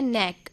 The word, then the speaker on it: knack: और आज का हमारा इंग्लिश का शब्द है नैक, जिसकी स्पेलिंग है KNACK।